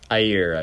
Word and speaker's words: Jair: This is a country with a somewhat authoritarian leader in, I think it's Jair Bolsonaro. You think I'm pronouncing that right? – Jair, I believe. – Oh, Jair Bolsonaro.